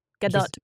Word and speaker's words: Gadot: It's not Gadot and it's not Gadot. It's kind of in the middle there.